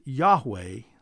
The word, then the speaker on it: Yahweh: But according to all Hebrew scholars, that is probably not the correct pronunciation. From all we can tell, that the name was pronounced Yahweh, spelled Y-A-H-W-E-H in English.